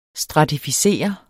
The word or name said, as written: STRATIFICERET